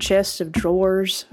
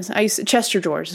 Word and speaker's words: chest of drawers: I used to call it chest of drawers. Well, that's actually, I said it right that time. Chester drawers is what a lot of us used to call them.